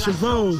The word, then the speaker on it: Chevaux: A-B-O-D-E. And my name is Chevaux. C-H-E-V-A-U-X.